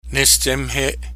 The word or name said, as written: Nestsemhe'e